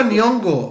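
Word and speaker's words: Nyong'o: actriz eh, fue no, Lupita Gongyo o algo así, no sé cómo se pronuncia. Lupita. – ah, Lupita Nyongo. Este...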